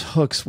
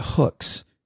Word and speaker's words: Hooks: But Paula, people around here pronounce hooks with like three syllables. It isn't hooks, H O O K S, which is the way you spell it. It's like hooks.